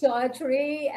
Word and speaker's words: Audrye: My name is Audrey and I spell it Audrye.